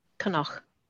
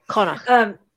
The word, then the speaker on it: Connaught: C-O-N-N-A-U-G-H, Connaught? – Connaught. – Okay. – Connaught.